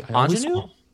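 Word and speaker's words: Aunjanue: Anjanine.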